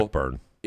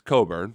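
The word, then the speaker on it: Kofi Cockburn: Cockburn. – End up. It's Coburn, pronounced Coburn, okay.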